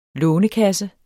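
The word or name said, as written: LÅNEKASSE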